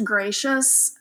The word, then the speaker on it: Gratias: It is, my last name is pronounced Gracious. It's not my fault. I married it, but it's not spelled like you would think. Goodness Gracious is spelled. It is, it looks like Gratias, G-R-A-T as in Tom, I-A-S as in Sam.